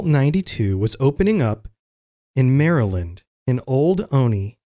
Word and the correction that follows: Olney: Olney, Olney, I think is the way you pronounce that,